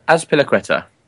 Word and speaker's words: Azpilicueta: Azpilicueta.